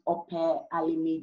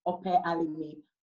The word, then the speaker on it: Ope Alimi: So my name on Instagram is Ope Alimi John. Ope Alimi.